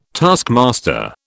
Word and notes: taskmaster: British English

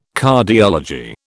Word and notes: Cardiology: British English

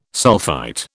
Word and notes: sulphite: British English

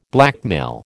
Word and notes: blackmail: American English

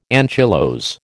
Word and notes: anchylose: American English

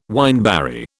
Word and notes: wineberry: British English

